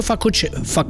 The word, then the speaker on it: facocero: L'accento giusto è, è proprio facocero.